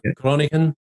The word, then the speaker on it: Groningen: Next week, you're going to be, I'm going to see how much my pronunciation guides help me, but Lille, Groningen, and Eindhoven. – That's correct. Yes.